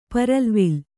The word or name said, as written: ಪರಲ್ವಿಲ್